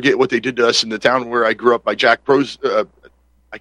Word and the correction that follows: Posobiec: Posobiec. – I always try to say it too fast, but Jack Posobiec.